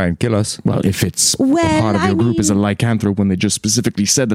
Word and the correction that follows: lycanthrope: Oh, they assume sir, you put the emphasis on the wrong syllable. – Lycanthrope? – Lycanthrope?